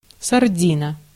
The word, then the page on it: сардина: /sɐrˈdʲinə/; sardina